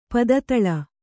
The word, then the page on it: ಪದತಳ: pada taḷa